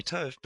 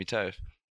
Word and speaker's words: Tofe: So it's funny because a mate just started calling me Tofe because it's like a derivative from Topher, so like Chris Topher. And then from there, mate, and in Australia, we like to get lazy with our syllables, you could say. So like even Topher became too much, so people just started calling me Tofe.